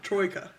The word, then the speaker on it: Troika: Put this accent on it, and I'm like, no, Troika.